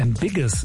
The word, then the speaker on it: Ambiguous: Ambiguous, beziehungsweise Ambiguous, so wird es ausgesprochen, Desire. Ambiguous, Desire.